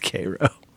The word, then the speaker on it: Cairo: Believe it or not, it's pronounced Cairo.